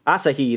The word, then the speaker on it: Asahi: Asahi, I think, is how it's said. – Asahi? – Asahi, that's it.